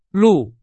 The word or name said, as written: 路